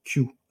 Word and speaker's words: CUE: langage Q. Alors je, pense que ça se prononce comme ça. C-U-E pour configurer, unifier, exécuter.